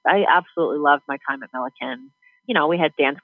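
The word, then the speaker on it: Millikin: Yeah, at Millikan.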